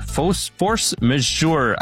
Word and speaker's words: force majeure: This week, Oracle invoked force majeure. Am I saying that right, Lou? – Basically.